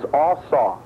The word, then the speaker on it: asah: The Hebrew word for make is asah. It's spelled A-S-A-H.